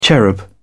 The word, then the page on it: cherub: British English